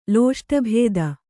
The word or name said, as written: ಲೋಷ್ಟಭೇದ